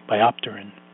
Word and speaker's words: biopterin: Downstream effects of reduced BH4 and SAMe levels are numerous, as MTHFR is a regulator of methylation and biotin, okay, biopterin. That PT is like saying pterodactyl. Okay, English is a tough language. Okay, why do you not produce? Why do you not pronounce the P?